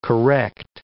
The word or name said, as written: correct